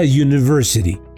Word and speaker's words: university: En un sentido similar, tenemos la palabra university. Si bien comienza con U, el fonema que escuchamos al pronunciarla es Y, sonando el inicio como consonante.